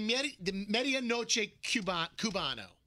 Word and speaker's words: medianoche cubano: I had the medianoche cubano, and he ate mine. – Yeah. – So I ate yours. – You have mispronounced like 10 words in the past 10 minutes. – It's medianoche cubano sandwich.